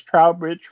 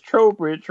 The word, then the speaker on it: Trowbridge: I've been mispronouncing as Trowbridge Road, but it is Trowbridge Road, and it is available October 6th, right? – Yes.